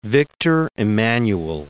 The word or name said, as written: Victor Emmanuel I